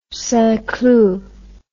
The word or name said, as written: St Cloud